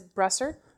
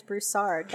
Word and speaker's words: Broussard: Otherwise known as Brusser. – That's not how you say it. – Sometimes people mispronounce it, but we're correcting that today on the podcast. – That's right. It is Broussard, not correct. Broussard.